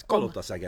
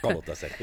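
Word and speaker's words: Kalotaszeg: Kolotaseg? Almost right. Kolotaseg, as you pronounce. You always pronounce Kolotaseg.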